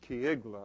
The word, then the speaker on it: Keilah: I just want you to know that I looked up in the pronunciation book in my office, this city, and the pronunciation is Keilah.